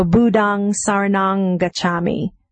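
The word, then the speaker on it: Buddhaṃ saraṇaṃ gacchāmi: You'll see a dot under the M, in the refuges. That means that that's pronounced like an ng. So Budang Saranang Gacchami, Dhammang Saranang Gacchami, Sanghang Saranang Gacchami.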